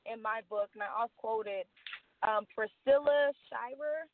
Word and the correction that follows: Shirer: Shirer. uh-huh. yeah. Oh, that's how you say it.